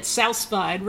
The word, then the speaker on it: sous vide: It's sous-vide, right? – That's 100% it, sous-vide. We're going to do the, or as the fancy people would say, sous-vide, which is spelled S-O-U-S, second word, V-I-D-E.